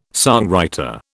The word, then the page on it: songwriter: British English